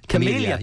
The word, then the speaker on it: camellia: Anyway, I have a camellia plant, is it? – Camellia plant. – You're nearly there, yeah, camellia.